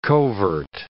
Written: covert

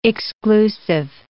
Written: exclusive

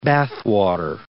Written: bathwater

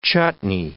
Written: chutney

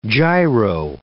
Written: gyro

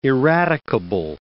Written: irradicable

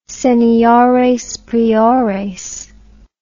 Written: seniores priores

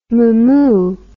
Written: mamou

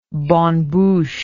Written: bonne bouche

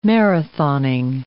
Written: marathoning

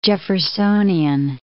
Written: Jeffersonian

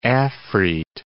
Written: afreet

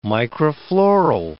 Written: microfloral

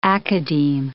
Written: academe

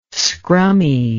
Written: scrummy

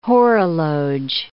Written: horologe

